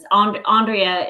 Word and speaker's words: Andrea: Andrea is typically Andrea.